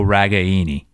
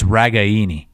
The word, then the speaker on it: Ragaini: It's Ragaini. – No, what? No, it's Ragaini. – Nope. It's Ragaini.